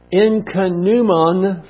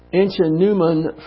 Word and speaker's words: ichneumon: The Septuagint translates the Hebrew word swarms as a dog fly, a blood-sucking insect, and the Incanumon fly, this is another type of insect, a fly, is spelled I-C-H-N-E-U-M-O-N. The Incanumon fly, which deposited its eggs on other living things so the larva could feast upon it.